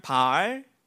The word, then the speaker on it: 바알: 아, 이것은 발, 알 바알, 발음이 힘드네, 발. 네, 바알 선지자들에 대한 바알의 응답.